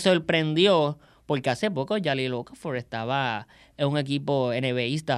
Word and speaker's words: Okafor: Ocafort. – Okafor. – Muy bien, muy bien la pronunciación.